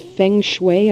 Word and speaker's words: feng shui: And the feng shui, am I pronouncing that correctly? – That's correct.